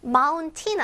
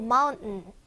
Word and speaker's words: mountain: mountain 아니고 mountain. 이렇게 살짝 묵음이 납니다.